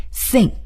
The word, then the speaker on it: think: 再来学习另外一个动词，如果我们说我想怎么怎么样啊啊，这个我认为怎么怎么样，这时候呢要用 think。T-H-I-N-K, think. 啊，注意，这里又涉及到 th 的发音，我们一定要把这个舌尖啊伸在啊上牙齿和下牙齿中间进行摩擦。